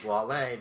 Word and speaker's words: Wale: Wale? – Wale, that's how you say it. W-A-L-E. Oh. It's spelled whale.